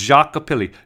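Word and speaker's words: Xochipilli: It's spelled with XO, if you remember, but it's pronounced Jacopili.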